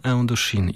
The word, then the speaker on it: Indochine: pamiętam, jak wiele lat temu na antenie Nocy Uberniego zastanawialiśmy się, jak wymawiać ostatecznie nazwę tego zespołu i stanęło na Indochine.